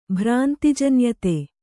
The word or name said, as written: ಭ್ರಾಂತಿಜನ್ಯತೆ